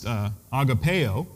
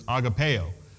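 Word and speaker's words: agapeo (ἀγαπάω): And that word love means, it's pronounced agapeo. You've heard of agape love. That's the noun. So the verb is agapeo.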